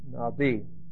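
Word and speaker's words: Nabi (נביא): The word for prophet is the word Nabi. It's Aleph is silent. Nabi. Okay? N-A-B-I.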